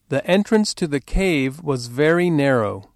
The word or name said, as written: entrance